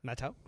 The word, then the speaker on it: Matthau: Matthau? – Matthau? How do you say it? – Matthau. – Matthau? – Yeah. – He likes the hard T's.